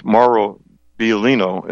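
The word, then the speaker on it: Mauro Biglino: Mauro Biolino is how he pronounces his name, a world-class ancient ancient manuscript translator, including Bible.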